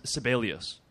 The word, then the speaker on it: Sibelius: Oh, Gene's, maybe I'm not pronouncing it, Sibelius. – Gene Sibelius Park. – Named after the... – You are pronouncing it right.